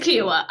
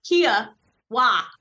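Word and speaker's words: Kiawah: It's the Kiowa versus Kiowa argument. But anyway, Ocean Course It's Kiowa. Oh, that's what I said. We were talking about it over here. Like, look at how it's spelled. It's literally spelled like the car.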